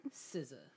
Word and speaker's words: SZA: The only new music I know about is the SZA album. Am I saying her name right? – SZA.